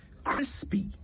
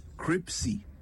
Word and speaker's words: crispy: Crispy. C-R-I-S-P-Y. Crispy. Not cripsy. Crispy.